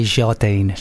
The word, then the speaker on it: Jardine: Is it Jardine or Jardine? How do you pronounce it? – I like to say Jardine.